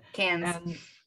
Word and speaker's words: Cannes: Cannes. Never know how to say it. – Cannes.